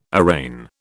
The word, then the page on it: arraign: British English